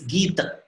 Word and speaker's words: Gita: The real word, the Hindi pronunciation of the, is Gita. It's not Gita, but it's Gita.